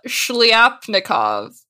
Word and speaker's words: Shlyapnikov: But he jumps to Kollontai's comrade, Alexander Shlyapnikov. Alexander Shlyapnikov. – Let me give it a try. Shlyapnikov. Yeah, there you go.